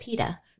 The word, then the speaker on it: pide: Yeah, because when we go to the chef's table, which is a Turkish restaurant, he has pite, P-I-T-E, I think, right? – And P-I-D-E. So it's You're right. It's P-I-D-E. – Mm-hmm. But he pronounces it pita, yeah.